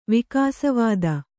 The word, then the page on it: ವಿಕಾಸವಾದ: vidāsa vāda